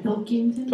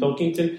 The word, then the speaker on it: Pilkington: Pilkington. – Pilkington.